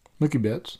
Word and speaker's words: Mookie Betts: Mookie, how do you say his name? – Mookie Betts, that's right.